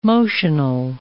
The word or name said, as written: motional